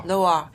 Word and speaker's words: Loire: Because I'm really, is it Loire? – Loire. – Did she like the river?